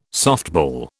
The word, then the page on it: softball: British English